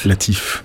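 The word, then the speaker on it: Lateef: Latif. – Je veux toujours prononcer Latef sans savoir Latif.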